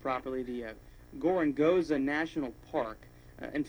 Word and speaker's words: Gorongosa: Yeah, you got the pronunciation right, Gorongosa.